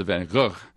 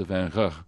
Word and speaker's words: Van Gogh: It was basically it's something on the order of Van Gogh. – Yes. – And in northern Holland, it's sort of a hard G. It's a Van Gogh.